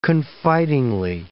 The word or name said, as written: confidingly